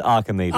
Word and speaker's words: Archimedes: Yeah, no, it's pronounced Archimedes.